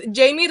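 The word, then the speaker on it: Jeimy: Yeah, I just Jamie Reyes, my name.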